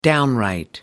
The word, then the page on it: downright: American English